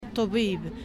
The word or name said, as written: Toubib